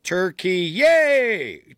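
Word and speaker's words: Türkiye: R-K-I-Y-E. Okay? Well, in 2022, the Republic of Turkey began promoting the use of Turkey. It's how they, it's like T-U-R-K-E-E-Y-E-A, like Turkey, yay! Turkey, yay, is how it's pronounced.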